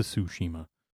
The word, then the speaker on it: Tsushima: But it's not even, it's spelled with a T, T-S. – Yeah, yeah. – To Tsushima.